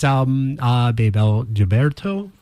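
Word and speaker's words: Bebel Gilberto: That is Babel Gilberto.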